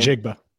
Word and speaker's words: Njigba: Njigba.